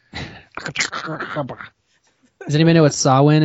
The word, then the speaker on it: Samhain: But no, it's pronounced Samhain because Ireland has its own special rules.